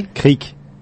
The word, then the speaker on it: Kriek: So I guess the only difference there is that we say it Kriek as if it were spelled like a little body of water flowing down a hill. We would say Kriek, but there's a roll in there.